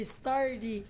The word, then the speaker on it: Stardy: C'est Stardy. Stardy. Alors, c'est S-T-A-R-D-Y, mais prononcé Stardy.